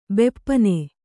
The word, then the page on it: ಬೆಪ್ಪನೆ: beppane